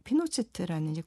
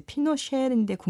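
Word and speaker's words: Pinochet: 근데 그 70년대, 80년대 이때 그 피노체트라는 이제 군사 독 원래 발음은 이제 피노쉐인데 국내에서 피노체트라고 불러요. 그냥.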